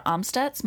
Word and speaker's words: Amstutz: You can find me on all social platforms at Sandra Amstutz.